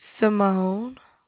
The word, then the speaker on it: Samone: Simone. – How you spell that? – S-A-M-O-N-E. – S-A-M-O-N-E.